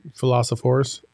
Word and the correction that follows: Dilophosaurus: Dilophosaurus. – Dilophosaurus.